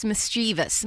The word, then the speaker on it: mischievous: It's mischievous. Mischievous. Where is that extra syllable coming from? Well, because most people think it has an I in it, like I did until right now.